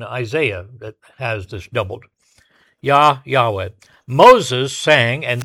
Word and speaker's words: Yahweh: We have just the YHWH because those are the representative consonants of the Hebrew without the vowel points, but it would be pronounced Yahweh, best we can understand.